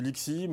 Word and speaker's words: LXC: Bon, on dit LXI, mais bon, LXC va très bien aussi.